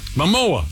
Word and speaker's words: Momoa: And Jason Ramona, how do you say his name? – Momoa? – Marona? – Momoa? – Momoa.